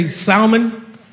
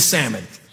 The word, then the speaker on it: salmon: I had to practice that because it's spelled C-A-L-F. And I'm Puerto Rican, so I go, calf. It's kind of like salmon. That's the L, so I say salmon. And my wife goes, it's salmon. I don't know why.